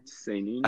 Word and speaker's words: seinen: Like obviously a shonen anime could probably portray an emotion as viscerally and as painfully as a more violent, samey anime? – Seinen? – I don't know. How did you pronounce it? Samey? – I think it's pronounced seinen because it's an N.